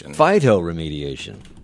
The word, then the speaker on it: Phytoremediation: Active Modular, I hope I say this right, Active Modular Phytoremediation System. – Phytoremediation.